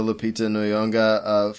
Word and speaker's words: Lupita Nyong'o: You also get, she talks about the Lupita Nyong'o, photo with the white dots and her character.